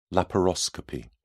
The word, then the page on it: laparoscopy: British English